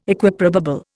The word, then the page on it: Equiprobable: Indian